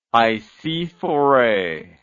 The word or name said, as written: icaaaa